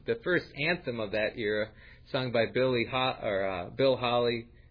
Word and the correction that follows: Haley: Haley. Am I pronouncing right?